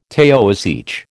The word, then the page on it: Taoiseach: American English